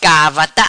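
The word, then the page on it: Gavarta: patua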